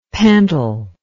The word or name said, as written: pentyl